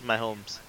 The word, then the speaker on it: Mahomes: Is that Mahomes? – Nope. Yep. Yeah, he is Mahomes.